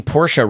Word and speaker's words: Porsche: Thank you for pronouncing Porsche right.